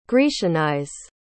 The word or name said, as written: grecianize